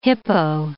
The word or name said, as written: hippo